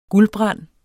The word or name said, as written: GULDBRAND